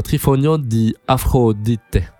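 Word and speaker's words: Trionfo di Afrodite: Trifonio di Afrodite. Je crois que ça se prononce comme ça avec l'accent. Ouais, je pense que tu l'as.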